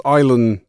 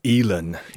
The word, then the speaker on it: Eilen: The name is spelled E-I-L-E-N, rather annoyingly. How are we supposed to be pronouncing it? – It's Elan Jewel. – Elan. – Yes. I mean, there's no way that you would know that other than just knowing it, isn't it? So it's an interesting name, but Elan is how it's pronounced.